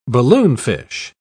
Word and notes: balloonfish: British English

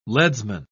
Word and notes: leadsman: British English

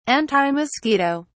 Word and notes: antimosquito: British English

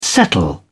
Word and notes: settle down: British English

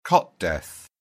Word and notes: cot death: British English

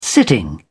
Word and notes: sitting: British English

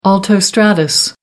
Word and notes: altostratus: American English

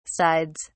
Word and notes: sides: British English

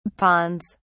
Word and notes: ponds: British English

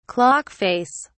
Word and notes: clock face: British English

